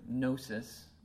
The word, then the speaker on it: gnosis: One is the word gnosis, which starts with a silent G, G-N-O-S-I-S.